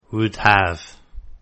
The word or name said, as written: would have